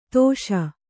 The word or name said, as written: ದೋಷಿ